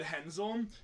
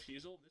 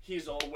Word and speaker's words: Hezel: Well, last time I said Hensel instead of Heasel. This is really close. Oops. There we go. So I pronounced his name wrong. So this is Josh Heasel with Furthest Point.